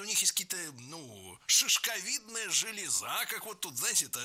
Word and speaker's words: железа: Далее разберем слово «железа», которое в начальной форме ударяется однозначно – «железа».